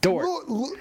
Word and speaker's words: Dort: Dort.